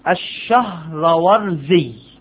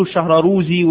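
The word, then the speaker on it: الشهرزوري: ابن الصلاح هو تقي الدين أبو عمر عثمان الشهرورزي، يعرف طريقة نطق الاسم، اللي بيقوله الشهراروزي واللي بيقوله مش عارف ايه الشهرة مش عارف ايه، الشهرورزي فعولعلعي، الشهرورزي اسمه ازاي بيتنطق كده، الشهرورزي. احفظ ضبط الاسماء، الفرق بين طالب علم وطالب علم اخر ضبط اسماء العلماء، ده يدل على الدراية والفهم. الشهرورزي درب نفسك عليها، اسمه الشهرورزي خلاص.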